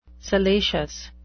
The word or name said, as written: salacious